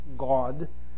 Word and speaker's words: Gad: these are the families of the sons of Gad, or actually it's probably better pronounced God, according to those who were numbered of them, 40,500.